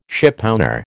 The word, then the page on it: shipowner: American English